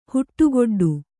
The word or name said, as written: ಹುಟ್ಟುಗೊಡ್ಡು